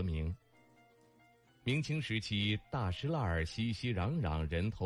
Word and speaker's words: 大栅栏: 对于外地人来说，大石栏是个第一次读出来都会折在这里的坑。大栅栏，北京人读作大石栏，位于前门外，兴起于元代。